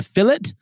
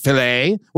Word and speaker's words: filet: And they also, you know, they call it a fillet. Absurd. You're close enough to France to know that it's pronounced filet.